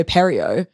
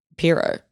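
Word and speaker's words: Pierrot: But I was saying Studio Perio. – Mm-hmm. – I think it might actually just be Piro. – Yeah, I think it is Piro, yeah.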